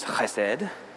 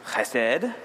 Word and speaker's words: chesed: The correct mispronunciation is chesed. Chesed.